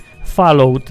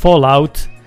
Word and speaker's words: Fallout: No to tak, pierwsza uwaga, że nie da się tego słuchać, bo ja nie mogę, ja mam alergię, jak ktoś mówi Fallout zamiast Fallout,